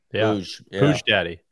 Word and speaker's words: Puig: Yeah, I don't know how we get to that from Puig is what I was ready to say there, but apparently that's how you say it, which has been news to me this week.